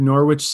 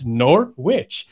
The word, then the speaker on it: Norwich: Yeah, like they just don't pronounce half of the letters here. It's like Norwich. It's like, okay, but okay, there's a W.